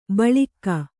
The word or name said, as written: ಬಲಾಕೆ